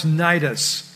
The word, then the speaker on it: Cnidus: Sailing slowly for many days, we came with difficulty as far as Cnidus.